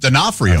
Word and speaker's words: D'Onofrio: Born in 1959, Vincent D'Onofrio? – D'Onofrio. – D'Onofrio? – Yeah.